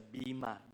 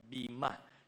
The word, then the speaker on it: Bema: This is also known as the Bema, B-E-M-A, or sometimes known as the B-I-M-A, Bema. So the Bema or the Bema, parehas lang pronunciation, whether it's E or I,